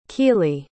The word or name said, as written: Keeley